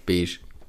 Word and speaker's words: Beige: Weil Beige schreibt man ja auch Beige. Das ergibt gar keinen Sinn, weil wieso schreibt man B-E-I-G-E und dann heißt es Beige? Wo ist das G hin? Wo kommt das SCH her?